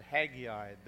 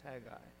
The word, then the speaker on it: Haggai: Some will pronounce it Haggai. They're wrong. It's Haggai, if you know Howard Cosell.